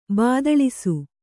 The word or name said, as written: ಭೂತೊಳಸಿ